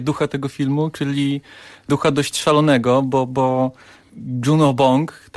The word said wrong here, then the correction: Bong Joon-ho: tak chyba się wymawia jego nazwisko? – Bong Joon-ho.